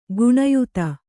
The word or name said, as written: ಗಣ್ಯತೆ